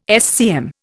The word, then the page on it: SCM: Indian